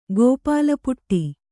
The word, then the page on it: ಗೋಪಾಲಪುಟ್ಟಿ: gōpāla puṭṭi